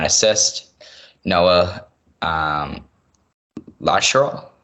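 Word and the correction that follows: Larochelle: I think I pronounced that right. – La Rochelle. – You got it? What was it? – La Rochelle. – La Rochelle. There you go.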